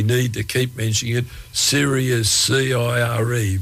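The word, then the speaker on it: CIRE: There's a few different pronunciations of it going around, but it's definitely Siri, an acronym.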